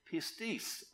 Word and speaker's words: pistis: And I will tell you, it comes from a Greek word, and you can look this up yourself, and it's spelled P-I-S-T-I-S, and it's pronounced in this way, pistis, or pistis.